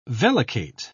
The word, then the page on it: vellicate: British English